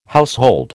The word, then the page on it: household: American English